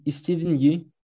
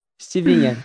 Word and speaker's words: Steven Yeun: E Steven Yeun, perdão, acho que eu falei errado, mas por Minari. – Steven Yeun.